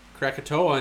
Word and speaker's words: Krakatoa: Krakatoa. – Do you know, is that how you say it right? – Yep. – Yeah. Okay. Krakatoa in Indonesia erupted with one of the most powerful explosions in recorded history.